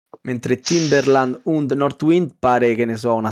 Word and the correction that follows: Nordwind: Allora, Timberland und Nordwind, Nordwind, ok, anno 1503 und anno 1701 e la terza coppia è Löwenherz und Entdecker.